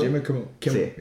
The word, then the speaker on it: Jimmy Kimmel: Jimmy Kimmel. – Jimmy Kimmel.